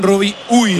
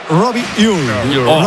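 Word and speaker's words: Robbie Ure: Como falle dos veces, le llaman Robi Ui. – Lo raro a la hora de pronunciarlo. – A ver, el club pasó incluso un audio, lo ha pasado. Y en concreto sería Robi Ur.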